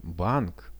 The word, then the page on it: bank: Russian